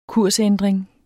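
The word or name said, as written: kursændring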